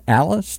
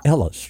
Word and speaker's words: Allis: So we're going to talk about all that and more as we talk with Jay, is it Allis? Did I say that correctly? – Yes, sir, Allis. – Allis. I want to say Ellis.